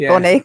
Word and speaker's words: Bonaire: Bonaire, did I say it right? – You did. You didn't say Bel Air. You said Bonaire. Good.